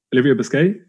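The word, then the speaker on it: Busquet: Like, I Haxton and Olivia Biscay. Is that how you say his name? Have I butchered that? – Mm-hmm. No, you got it. You did it perfect.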